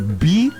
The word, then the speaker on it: BYD: O cara que vende carro, ele vende carro e ele fala BID. Não é BID, é BYD. – Ah, eu adoro quando fala inglês. – É porque o Y é a pronúncia, é o nome, é como se fala a letra Y em inglês.